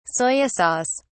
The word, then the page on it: soya sauce: British English